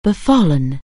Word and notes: befall: anglais

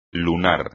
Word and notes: lunar: Spanish

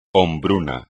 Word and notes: hombruno: espagnol

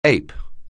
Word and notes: ape: anglais

